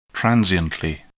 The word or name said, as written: transiently